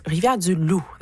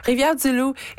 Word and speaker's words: Rivière-du-Loup: Rivière-du-Loup, there we go. I don't know why I said that wrong. Rivière-du-Loup, it is overcast and almost 9 degrees at this hour.